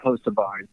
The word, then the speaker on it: Hocevar: I think Nice Motors, and by the way, I'm pretty sure I'm saying this correctly, but Carson Hocevar. I think I'm saying that right. – I wasn't going to come up with that. So, I'm 99% sure that it's Hocevar, the way you say that.